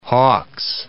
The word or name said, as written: Hawks